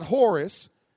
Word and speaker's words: Horus: is the Egyptian god Horus. You spell that H-O-R-U-S, H-O-R-U-S, Horus. He's an Egyptian god. Then you got the Greek god Dionysus. Dionysus, D-I-O-N-Y-S-U-S.